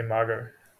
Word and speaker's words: McGough: I think you pronounce all the surnames wrong. – Fair enough. Mago? – Yeah, I say Mago. – Okay. – I say Mago, you say Margo. – Margo, yeah.